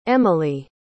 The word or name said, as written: emilee